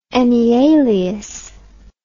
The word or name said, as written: enyalius